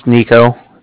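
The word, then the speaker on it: Niko: My name's Nico. That's N-I-K-O.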